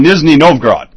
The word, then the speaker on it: Nizhny Novgorod: It's over in, let me pronounce this correctly. Over in Nizhny Novgorod. Yes, I think I got that right.